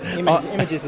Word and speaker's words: Imajes: I'm pronouncing it as it's spelt. – Images is about right. – I-M-A-J-E-S.